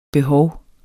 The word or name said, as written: behov